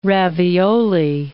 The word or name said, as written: ravioli